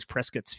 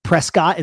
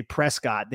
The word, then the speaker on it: Prescott: There's a town out here, Dan, called Prescott is what we would call it, but it's actually pronounced Prescott, and they are like very, very anal about that. Like if you were to go there and say Prescott, they would be like, actually it's Prescott, and be all pretentious about it.